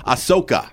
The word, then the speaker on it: Ahsoka: Ahsoka.